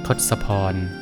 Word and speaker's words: ทศพร: สวัสดีครับพบกับรายการคำวัดวันนี้เสนอคำว่าทศพรคำว่าทศพรสะกดด้วยททหารสกสาราพอพานรอเรืออ่านว่าทศพร